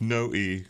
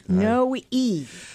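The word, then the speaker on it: Noe: No, it's No-E. – No-E. – Yeah, my father made a point of having everyone pronounce the E somehow, and that's because the family apparently in Kentucky dropped the E.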